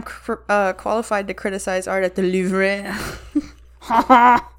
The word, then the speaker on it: Louvre: How do you say that? – The Louvre. – The Louvre. – No, actually that's right. Say Louvre.